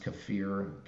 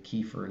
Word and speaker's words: kefir: Right away, I started eating raw food direct from farms, particularly raw dairy products, raw goat kefir, or it's actually pronounced kefir. Kefir is the American pronunciation.